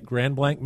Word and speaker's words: Grand Blanc: Well, this final news story that we've got here is up in your neck of the woods in Grand Blanc. Did I say that right? Grand Blanc, Michigan? – Yes, you said it right.